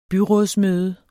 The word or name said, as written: byrådsmøde